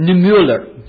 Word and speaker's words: Niemöller: But there's another man by the name of Martin and Niemöller. That's how it's said. I looked it up and Googled it. I've read it before, but I can't pronounce the name.